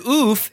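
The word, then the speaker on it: oeuf: "And oof is pronounced like enough, and oof is French for egg."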